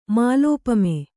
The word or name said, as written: ಮಾಲೋಪಮೆ